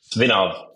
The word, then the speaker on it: Svinov: Svinov.